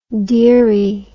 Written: dearie

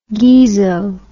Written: geezo

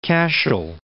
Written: Cashel

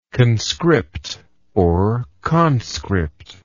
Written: conscriptable

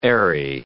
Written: airy

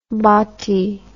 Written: bottie

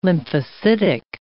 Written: lymphocytic

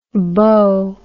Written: bo

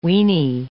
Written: wienie

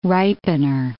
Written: ripener

